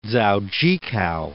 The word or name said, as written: Dzaudzhikau